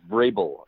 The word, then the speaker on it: Vrabel: Last name is Vrabel.